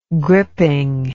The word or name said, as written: gripping